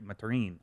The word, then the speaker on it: Mathurin: The guy in fade was Maturin, who I think, or Mathurin. It's actually Maturin, I believe, is actually supposed to be how it's pronounced, but Mathurin.